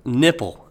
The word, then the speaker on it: Knipple: It's spelled K N I P P L E. So it's nipple but with a K in front, and I'm assuming the K is silent.